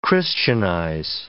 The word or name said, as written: Christianize